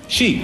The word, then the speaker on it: Xi: Rajoy nos recuerda cómo se pronuncia. – Xi. – Xi. Es Xi, Xi.